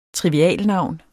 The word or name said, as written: trivialnavn